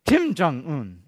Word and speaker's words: Kim Jong-un: He was like Sun Jung-un. Is that how you say his name? Kim Jong-un, okay?